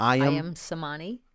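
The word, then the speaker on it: Ayam Cemani: I Am Samani.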